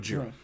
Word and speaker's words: Gojira: Tell us a little bit about Gojira. – Well, the Godzilla, you know, as we say in the United States, is, of course, a bowdlerization. It's Gojira is actually the correct pronunciation, and it's a portmanteau of two Japanese words.